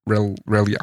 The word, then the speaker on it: Relyea: I think his name was Captain, it's hard to pronounce, Relia. – Okay. – R-E-L-Y-E-A. – R-E-L-Y-E-A. – Yeah. Relia. – R-E-L.